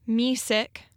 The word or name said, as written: Mesick